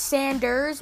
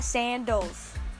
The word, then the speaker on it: Sanders: That's how you say it right there. Barry Sanders. – Barry Sanders. – It's Sanders, not Sandals.